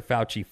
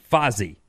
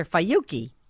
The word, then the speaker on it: Fauci: My mother calls Dr. Fauci Fozzy. – Oh. – I've corrected her multiple times, and she still says it wrong. Ugh. – Dr. Fauci.